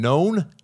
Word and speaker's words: Non: very fantastic listener named Known. Known. His name is spelled N-O-N. – Really? – And it's actually pronounced Known. – Known? – Known. – Easy alone. – Known. – It's not non.